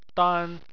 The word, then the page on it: dan: 1